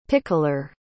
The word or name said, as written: pickler